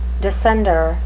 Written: descender

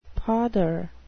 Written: pother

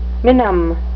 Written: minim

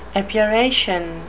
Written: epuration

